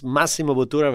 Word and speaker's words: Massimo Bottura: I think at the stage, did he pronounce it okay? – Yeah, perfect. Perfect. Okay. Actually, one of the first one who pronounced Massimo Bottura very well.